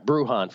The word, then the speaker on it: Bruján: All right, Bruján, for those who don't know, B-R-U-J-A-N, obviously with the Latin pronunciation, the Spanish pronunciation, of the Rays, 60 to 1 at BetMGM in the American League.